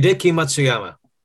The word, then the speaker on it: Matsuyama: Hideki Matsuyama.